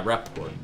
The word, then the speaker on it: Rapaport: I mean, it's Michael Rapaport, or however you pronounce his name. – Yeah, Rapaport.